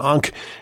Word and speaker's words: Ankh: How do you pronounce it? Ankh? – Unks. – Unks. Yes. Okay.